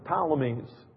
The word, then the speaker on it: Ptolemies: It starts with a P, but it's P-T-O-L-O-M-Y. I always pronounce it Ptolemies. Ptolemies, or however you want to say that.